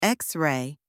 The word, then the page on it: x-ray: anglais